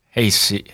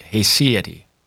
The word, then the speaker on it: haecceity: H-A-E-C-C-E-I-T-Y, for those keeping score at home. Hecate. – Hecate, I think that's correct.